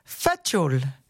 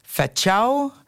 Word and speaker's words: facul: Our Slovenian word of the week is facul, an old Slovenian dialect word meaning a ruta or headscarf. You may also hear it pronounced or written in slightly different ways, including facau, facau, facau, and so on.